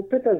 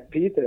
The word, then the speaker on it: Peter: Essa é a primeira afirmação importante, porque ele é um conterrâneo de Bento XVI, um jornalista alemão, por isso essa pronúncia peculiar do seu nome, né, que não é Peter,